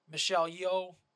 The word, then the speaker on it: Michelle Yeoh: And Michelle Yeoh.